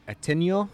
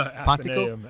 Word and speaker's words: Ateneo: And that was in the, let me try to say it, Ateneo? – No, Athenaeum. Athenaeum. – Athenaeum. – Ateneo, yeah. – So it's spelled A-T-E-N-E-O. – Yeah, that's well, that's like the Ateneo. – That's the Italian way.